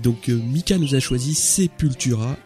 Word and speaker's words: Sepultura: alors je ne savais pas d'ailleurs qu'il fallait prononcer Sepultura.